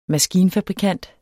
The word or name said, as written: maskinfabrikant